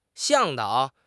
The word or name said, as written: 向导